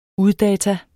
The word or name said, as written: uddata